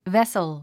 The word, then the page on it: vessel: vésl; ヴェスル